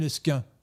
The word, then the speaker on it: Lesquen: Et à ce moment-là, on a francisé aussi la prononciation. Donc l'esguen est devenu l'esquin. L'esquin, qui s'écrit Q-U-E-N, qui se, qui se mm.